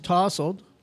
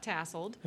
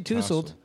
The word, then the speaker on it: tousled: So one more time, it's Tossled. – Tossled. – Tossled. – Not tasseled. – How do you spell that? – T-O-U-S. LED. – Thank you. Uh-huh. So if you see tousled, it's tousled.